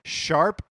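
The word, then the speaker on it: ♯: Yeah, which they have to do because the name of the game again is Tokyo Mirage Sessions Sharp FE, and I'm saying sharp because I know that that's how it's pronounced, but it's like a hashtag.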